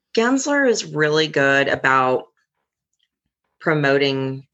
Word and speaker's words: Gensler: So now that you're working for a big firm now, which, how do you pronounce the company's name once more? Is it Gensler? – Gensler. Yeah.